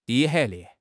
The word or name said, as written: de5